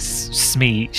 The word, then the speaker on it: Smee: I have such a hard time saying Smee. – It's Smee without the S-H. I know it's Smee, but I have a hard time pronouncing it.